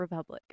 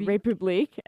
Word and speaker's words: Republic: Well, it's spelled the French way, so it always makes me want to say République. And I don't think that's how you say it. I think it's just Republic.